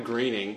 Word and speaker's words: Groening: And well, no, because it was like, I know that Matt Groening, because it rhymes with complaining, right.